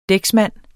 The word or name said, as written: dæksmand